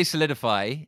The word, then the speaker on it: Soleadify: I say solidify. It's it's um, it's spelt not quite like that. It's spelt Soleadify.